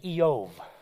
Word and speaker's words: Job: Unlike Job, which in Hebrew is actually Eov, which is quite a different pronunciation.